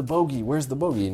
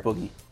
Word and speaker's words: bogey: where's the bogey? And you can see everywhere. – Pronounced boogie. – Bogey.